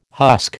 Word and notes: husk: American English

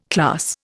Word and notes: class: Indian

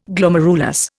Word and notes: Glomerulus: Indian

